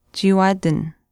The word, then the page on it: chiiwaatin: Chisasibi